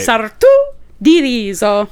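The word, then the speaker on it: Sartù di riso: With a, there's an accent on the U, right? – Sartu di Rizzo.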